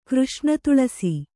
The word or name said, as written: ಕೃಷ್ಣತುಳಸಿ